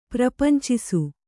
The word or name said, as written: ಪ್ರಪಂಚಿಸು